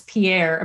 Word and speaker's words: Pierre: Our state capital it's pronounced around here, Pierre. It's Pierre. I mean, that's how it's written, Pierre.